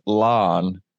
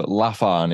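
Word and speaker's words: Laugharne: But this word, larn, is spelled, basically it's the word laugh with A-R-N-E. So laugharn is what I thought it was, but it's larn.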